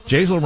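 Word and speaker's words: Jasiel: Jaisal rhymes with basil.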